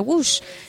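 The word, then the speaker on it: Łódź: hay una línea que está arriba de la Z y se pronuncia Wush.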